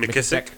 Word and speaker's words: McKissack: Southern Tales of the Supernatural by Patricia McKissack. I believe that's how you pronounce her name. – McKissack.